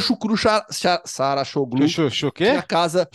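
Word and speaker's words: Şükrü Saracoğlu: Chucru Sarachoglu, se eu não me engano, a pronúncia correta.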